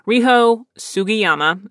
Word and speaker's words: Riho Sugiyama: And I saw that Rigo, uh, pardon me, I just butchered her name, Riho Sugiyama,